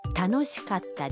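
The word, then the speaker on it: تانوشیکاتا (楽しかった): حالا گوش بدید و تکرار کنید در کلمه کاتا بین کا و تا یک مکس خیلی کوتاه داریم یعنی در واقع صدای ت با تشدید تلفظ میشه. – تانوشیکاتا دسو.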